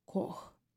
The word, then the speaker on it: Koch: Название этой корпорации произносится Coke Industries, а на русском фамилию переводят как Кох. По происхождению эта фамилия голландская, и раньше ее обладатели тоже произносили ее Кох но в какой-то момент стали произносить как «коук». Так что в английском варианте вы услышите «коуки сделали то-то или то-то», схожие по произношению с «кока-колой» на английском языке.